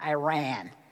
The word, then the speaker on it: Iran: And by the way, I kept saying Iran, and she kept saying Iran. And I go, okay, you're the expert. So now I'm saying it right finally. So everybody, say it together, one, two, three, Iran. That's how you say it, not Iran. Georgia boys go, Iran. But it's Iran.